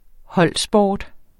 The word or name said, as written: holdsport